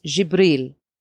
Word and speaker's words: Jibril: In Arabic, you pronounce it as Jibril.